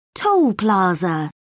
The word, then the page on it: toll plaza: British English